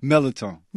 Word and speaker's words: mirliton: It's for stuffed mirliton. Am I saying that right? Mirliton? – Mirliton.